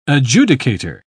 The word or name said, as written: adjudicator